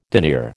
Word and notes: denier: American English

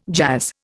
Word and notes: jazz: Indian

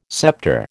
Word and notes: scepter: American English

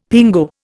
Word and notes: pingo: Indian

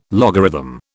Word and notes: logarithm: British English

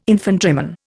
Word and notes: infantryman: Indian

